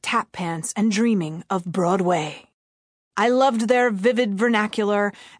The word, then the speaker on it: Broadway: That's where I learned that an actor's highest calling was the theater, and the ultimate goal for a true thespian was Broadway, emphasis on the way.